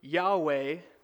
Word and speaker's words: Yahweh: The divine name, which most believe is pronounced Yahweh, implies continued, unfinished action.